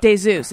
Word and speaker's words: Desus: Which some people mispronounce Desus. – Desus, they say. – Desus. – Yeah. – Desus. It's not that, you guys. It's Desus and Mero, and that's on Showtime.